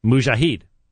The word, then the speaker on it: mujahid: You hear the M sound at the beginning and then the J-H-D root, mujahid.